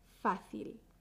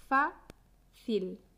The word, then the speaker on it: fácil: Vamos a empezar con fácil. Tiene dos sílabas. FA-CIL, FA-CIL. La sílaba fuerte es fa. Fa. Fácil. Fácil. Muchos y muchas estudiantes pronuncian fácil en lugar de fácil. Tata, fácil, tata, fácil.